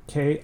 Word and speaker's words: Kinbaku: It is Kenbaku. – Phonetically spelt. – Kinbaku, just like it sounds.